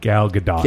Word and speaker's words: Gadot: Gadot, is that how you say it? – Gal Gadot.